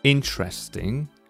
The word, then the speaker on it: interesting: Well, here, interesting, the third word is different again. That's where the stress falls on the first syllable, interesting, while in the other two it's on the second syllable in the middle.